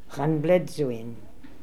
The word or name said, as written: Hanbledzoin